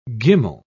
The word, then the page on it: gimel: British English